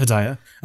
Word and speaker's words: Hedaya: Hedaya.